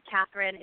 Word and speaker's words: Kathryn: Kathryn is spelled K-A-T-H-R-Y-N. And then my last name is Cloward, C-L-O-W-A-R-D.